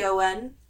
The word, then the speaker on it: Otton: Pauline Otten, I think is how you say it. O-T-T-O-N. – We'll go with it. – Otten. Yeah. Love it.